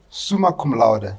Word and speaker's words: summa cum laude: Summa Cum Laude.